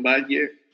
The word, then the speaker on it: Batlle: Quien quiera conocer más de la fundación, la página es María, el apellido se pronuncia Battle o Valle, he escuchado que lo pronuncian Valle.